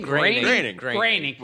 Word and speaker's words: Groening: Is it Groening? – Groening.